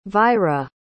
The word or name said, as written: vyra